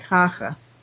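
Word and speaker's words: Hácha: And Hache have I been calling him Hacha? I don't know. It's pronounced something like Hacha, who was the president of the Czech president.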